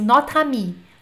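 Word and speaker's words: notre amie: É como se fosse assim: esse E morre e esse A toma conta, tá? Então é assim: nota-me, nota-me, Esse E morre e o A toma conta.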